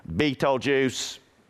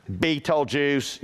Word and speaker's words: Betelgeuse: How do you pronounce Betelgeuse? Betelgeuse. Betelgeuse. You know, you can look that up and it says it like 14 times.